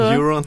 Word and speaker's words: Euron: Euron. Ja precis, han spelas av Pilou Aspek som ju ledde Eurovision 2014. – Eh, Exakt, det är kanske det hans namn kommer ifrån. – Precis. Euron, ah, Eurovision Song Contest.